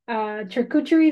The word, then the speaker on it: charcuterie: I think I had romanticized my first meal after this, where I was like, I'm going to have this charcuterie, is that how you say it? – Charcuterie.